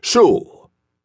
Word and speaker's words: Shull: "Shull rhymes with no bull."